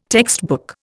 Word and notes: textbook: Indian

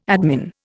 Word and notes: admin: Indian

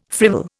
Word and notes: frivol: Indian

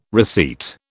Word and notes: receipt: British English

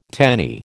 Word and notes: tenny: American English

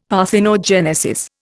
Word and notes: parthenogenesis: Indian

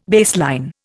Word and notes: baseline: Indian

